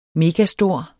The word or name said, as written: megastor